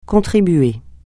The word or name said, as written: contribuer